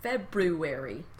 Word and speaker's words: February: February. – It's pretty. It's gorgeous. Foreign. – There's an extra R in there that nobody pronounces.